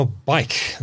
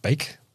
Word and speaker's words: BAIC: Now, bike. I think that's how you pronounce it. Not bake. – Bike. – Bike. – B-A-I-C. – B-A-I-C.